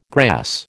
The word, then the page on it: grass: American English